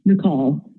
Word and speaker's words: Nikkal: So tell me about your relationship with, am I pronouncing it right? Is it Nickel Farm, N-I-K-K-A-L in Northern Colorado? Is that right? – Nicole Farm.